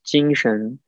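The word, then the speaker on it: jing shen (精神): So the second character, as you can see, is the same as the second character in the first word phrase, right? So it's together is pronounced as jing shen. So the first one is jing and second character is shen.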